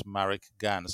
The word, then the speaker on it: Marricke Gane: which is Marricke Gane. So that's spelled M A double R I C K E uh, and then Gane, which is G A N E, all one word, Marricke Gane.